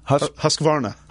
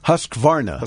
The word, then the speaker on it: Husqvarna: Husqvarna. – Husqvarna.